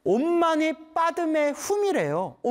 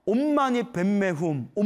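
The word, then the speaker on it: 옴마니 빠드매훔이래요 (ओं मणि पद्मे हूं): 옴마니 밤매움, 옴마니 밤매움이 옴마니 밤매움, 산스크리트어 발음으로는 옴마니 빠드매훔이래요. 옴마니 빠드매훔. 근데 빨리 발음하면 옴마니 빤매훔, 옴마니 빤매훔 이렇게 된대요. 티베트는 옴마니 뱀매훔, 옴마니 뱀매훔, 뱀매훔 한대요. 한국 발음은 옴마니 밤매훔.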